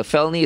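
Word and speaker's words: Phelony: P-H-E-L-O-N-Y. A felony is a serious crime, obviously, if you spell it with an F. – Yeah, it's not felony. It's felony. – Oh, no, it's felony, pronounced felony as well.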